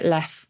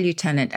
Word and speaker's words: lieutenant: And I have to I have to address though why is it left when it's spelled Liu and the idea we think is that someone misread the U for an F because the characters looked very similar centuries ago. – And oh. – um, and that's why we pronounce it that way, even though we didn't change the spelling.